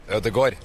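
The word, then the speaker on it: Ødegaard: What about Odegur? Odegar? – Odegor. – Odegur? – Yeah. – In Brazil, we say Odegar.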